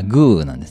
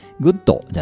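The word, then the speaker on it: good: で、まあ、これもカタカナにしたらグーなんですね。江戸晴美さんがグーグーグー言ってたのは、あれ、非常に正しい発音だと僕は思ってます。グー、グー、グーですね。グッドじゃないです。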